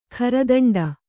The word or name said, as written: ಗರ್ದುಂಡಿ